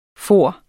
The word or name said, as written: farent